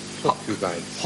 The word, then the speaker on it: Hawcubites: Hawkubites. – Hawk? – Yes. – How do you spell it? – H-A-W-C-U-B-I-T-E-S.